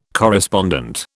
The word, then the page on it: correspondent: British English